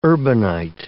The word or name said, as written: urbanite